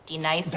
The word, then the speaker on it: D-Nice: Do you mean Denise? – Son of a You say your name right now. – Denise? – Say it right. – Denise. – Correctly. – Denise. – Right. – Denise. – Right. – D-Nice. – That's better.